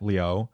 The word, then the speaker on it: Liu: And then, you know, for comparison, Ken Liu, that last name, Liu, it's usually, mm-hmm. you could say it like that, Liu.